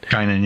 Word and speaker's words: Guinan: the woman who plays Guinan. – Guinan, yeah.